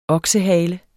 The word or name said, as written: OKSEHALER